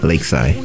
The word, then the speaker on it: Leksai: You know, I go by the name Lakeside. You know, everybody knows me at Lakeside Beats, Beats by Lakeside. – Hey, you got to spell that, bro. – Everybody's asking it. Well, anyway, it's spelled L-E-K-S-A-I. Lakeside.